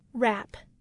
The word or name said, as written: wrap